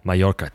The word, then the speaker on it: Mallorca: They play, and correct me if I'm wrong, Mallorca. Is that how you pronounce it? – Mallorca.